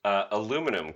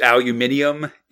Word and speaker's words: aluminium: So why can't Americans say aluminum correctly? And it's because we actually already are. – Yeah, I believe it's aluminium in England, but that's weird, buddy.